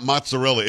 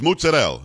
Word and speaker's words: mozzarella: And by the way, those who are watching, it's not mozzarella, it's mozzarella. You have to say it like this with your head.